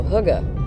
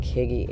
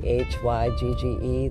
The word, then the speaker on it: hygge: And uh, it looks like Higgy, H Y G G E, that's how it's spelled, but it said hygge, I believe.